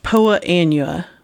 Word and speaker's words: Poa annua: Poa annua, which is annual bluegrass.